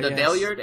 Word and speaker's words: Valeyard: But the Valeyard, am I pronouncing yeah.